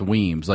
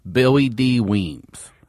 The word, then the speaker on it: Williams: I told Jay, like, when you say Williams in Baltimore, it's Weems. Like, Billy D. Williams is pronounced like this. Billy D. Weems.